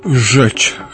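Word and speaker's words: rzecz: И польское слово «жечь», что-то вроде «дело», «вещь», «сущность». Это так и произносится, а на письме начинается с буквы «Р».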